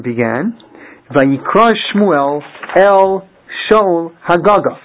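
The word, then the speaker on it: Hagaga (הגג): It's spelled hey gimel gimel. We pronounce it Hagaga. Normally there'd be another hey at the end of that word, but there isn't.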